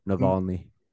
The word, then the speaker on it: Navalny: Navalny.